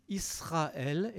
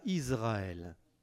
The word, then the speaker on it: Israël: Vous remarquerez au passage que je dis Israël et pas Israël.